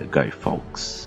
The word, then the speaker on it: Guy Fawkes: o Guy Fawkes. Eu, eu pronunciei certo, Nelson? É Guy Fawkes. – A princípio, sim.